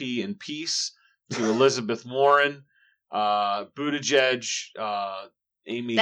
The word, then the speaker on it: Buttigieg: Must be a hard German name to pronounce. It's Buttigieg. Just Buttigieg.